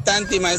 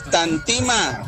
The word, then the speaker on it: Tantima: Emiliano, no es Tántima, es Tantima.